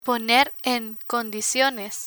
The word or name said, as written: poner en condiciones